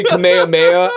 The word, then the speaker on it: Kamehameha: Even though it's actually Kamehameha, I know that.